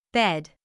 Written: bed

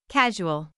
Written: casual

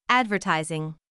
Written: advertising

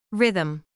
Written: rhythm

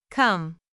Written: come

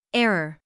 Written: error

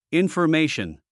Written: information

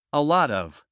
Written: a lot of

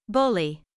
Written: bully

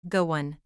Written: go on

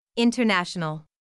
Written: international

